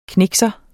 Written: knikser

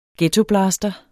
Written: ghettoblaster

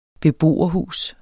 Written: beboerhus

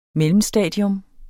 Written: mellemstadium

